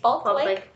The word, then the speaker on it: Falk Lake: Can you spell it? – F-A-L-K? – Yes. – Falk Lake.